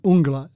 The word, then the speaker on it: Uungula: I'm not saying I'm an expert on it, but I actually say Uungula. So they probably used Uungula as their pronunciation of it in the news, and hopefully that's right. But that's the double U at the beginning of it is what says to me it's Uungula rather than Uungula.